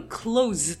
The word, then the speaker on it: close: で、そもそもあの、閉めるの close と同じスペルで、図じゃなくてすの発音になるのが近いという意味になるんです。